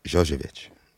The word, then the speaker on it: Jovovich: How did you get Mila Jovovich to do that joint? Did I say her name right? – Yeah. – Okay. Jojevic. – Oh, okay. – Jojevic. Yeah. – So, no, you didn't.